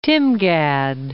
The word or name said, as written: Timgad